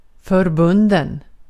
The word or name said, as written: förbunden